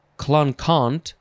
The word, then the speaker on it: Clinquant: Next is, you could pronounce this a few ways, clinkant, or clonkant, but it's spelled Clinquant. C-L-I-N-Q-U-A-N-T. I think I'll just try and say clinkant.